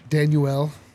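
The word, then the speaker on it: Danuel: Daniel House. – Okay. – Rotting away on Philly's bench. – Daniel. – Yeah, I think that's actually how it is pronounced.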